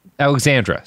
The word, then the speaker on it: Alexandra: First of all, pronounce her last name right. – Sophia Alexandria. – Nope. – What? Alexandra, sorry.